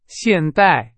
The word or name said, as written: 现代